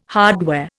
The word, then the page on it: hardware: Indian